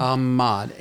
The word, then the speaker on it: Ahmad: Not sure how to pronounce it. Ahmad, I guess. I'm not sure. – Ahmad. A-H-M-A-D.